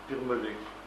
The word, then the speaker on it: Birmelet: Birmelet. C'est comme ça se prononce.